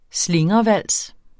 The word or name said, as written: slingrevals